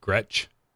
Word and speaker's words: Gretsch: Is that how it's spelled or how it's said? Gretsch? – Yes.